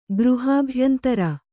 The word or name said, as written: ಗೃಹಾಭ್ಯಂತರ